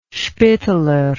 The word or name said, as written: Spitteler